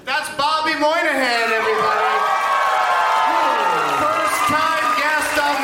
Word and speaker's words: Moynihan: Moynihan is it spelled out phonetically to begin with. Moynihan. It's the Y is there. It's as easy as pie.